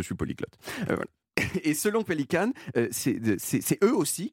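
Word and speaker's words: Pelikan: Ah oui, ça se prononce Pélican.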